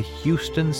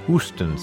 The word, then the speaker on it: Houston: On today's date in 1955, Leopold Stokowski gave his first concert as the new music director of the Houston Symphony, or as Stoki pronounced it, the Hooston Symphony.